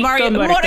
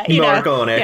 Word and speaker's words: Morricone: If you want to do it like the Italian way, it would be Morricone, but Morricone.